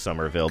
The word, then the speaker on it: Somerville: It's properly pronounced Somerville,